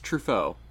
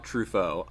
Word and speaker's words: Truffaut: I'm so bad at pronouncing French words. Truffaut. There we go. – Francois Truffaut.